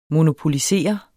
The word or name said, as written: monopolisere